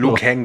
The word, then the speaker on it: Liu Kang: Die furchtlosen Kämpfer des Gottes Lord Raiden und der Prinzessin Kitana, Hongkong-Fighter Lu Zhang Habe ich das jetzt, oder wie wird er richtig ausgesprochen? – Liu Kang, glaube ich, wird er ausgesprochen. Kang.